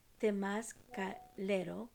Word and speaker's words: temazcalero: Again, that is pronounced temazcalero, and it's spelled T-E-M-A-Z-C-A-L-E-R-O, which is a word for the shaman that is part of the Temazcal.